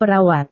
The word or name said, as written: perawat